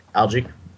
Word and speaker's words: algae: How do you say it? Alga? Algo? – Algae. – Algae. That's how you pronounce it? – Yeah. – A-L-G-A-E.